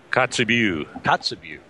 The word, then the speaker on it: Kotzebue: Kotzebue.